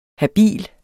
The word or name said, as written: habil